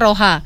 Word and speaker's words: Roja: Misalnya kayak Roja. Kita bacanya Roha.